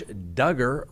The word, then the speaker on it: Duggar: Josh Duggar, rhymes with hugger, extends his stay in federal prison.